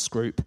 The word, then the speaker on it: Scroope: And when they did, there was a guy called Richard Scroope, spelled Scroope, but pronounced Scroope.